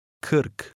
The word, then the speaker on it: kırk: It contains an undotted I, which is pronounced ı, kırk, kırk. – This sound is peculiar to Turkish and resembles somewhat the English schwa or a. It changes slightly depending on the sounds around it.